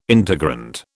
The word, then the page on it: integrand: British English